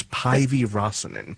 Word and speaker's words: Päivi Räsänen: I think it's Päivi Räsänen. – That's correct.